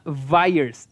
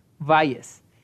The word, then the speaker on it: Vhyes: Ah, se llama, se llama Vires, se escribe B-H, o sea, V-H-Y-E-S, así se llama. – Vires. V-V, perdón, H-Y-E-S.